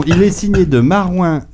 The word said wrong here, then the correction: Marouane: et Luasti, je le dis bien Marouane. Marouane, pardon.